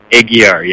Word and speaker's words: Aguiar: It's Aguiar. – Aguiar, yeah. – Aguiar. You just take out the A.